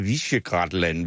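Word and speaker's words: Visegrád: Og Ota, de her fire, jeg tror, det hedder Visegrad-land, hvis man skal udtale det rigtigt, altså det er jo en klar udmelding, når de siger, ja, flygtninge er velkommen her, bare de er kristne.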